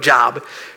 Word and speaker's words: Job: Now, in the book of Job, it looks like the book of Job because it's spelled J-O-B, but it's not the book of Job. It's the book of Job. That's how you pronounce it.